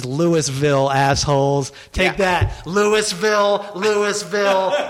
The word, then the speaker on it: Louisville: It's like you're not supposed to pronounce the O, the U, the I, or the S. It's just like Louisville,